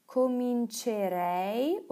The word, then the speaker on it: comincerei: Also zum Beispiel cominciare, beginnen, wird zu comincerei oder cominceresti, aber das I nach dem C fällt weg. Es wird aber gleich ausgesprochen.